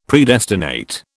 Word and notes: Predestinate: British English

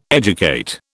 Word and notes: educate: British English

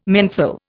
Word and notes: menthol: Indian

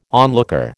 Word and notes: onlooker: American English

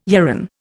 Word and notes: yarran: Indian

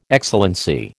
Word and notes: Excellency: American English

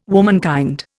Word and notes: womankind: Indian

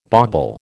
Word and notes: bauble: American English

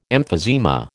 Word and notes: emphysema: American English

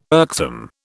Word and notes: irksome: British English

